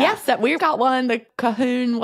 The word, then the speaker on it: Colquhoun: My family, I'm a Colquhoun. Is that how you pronounce it? – Nice. – C-O-L-Q-U. I can't. H-O something. That's not how we spell it here. We spell it Calhoun.